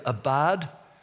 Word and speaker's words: abad: The Hebrew is abad, A B A D, forgive me if I've pronounced it wrong, and shamar, S H A M A R.